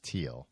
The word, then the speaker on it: Thiel: Do you say Thiel or Teal? – I've heard it's pronounced Teal. – Yeah, but don't you feel like a jerk? Like, yeah, because there's a H in there.